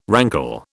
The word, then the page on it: rankle: British English